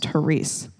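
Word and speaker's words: charis (χάρις): That's how you spell it. But it's pronounced charis.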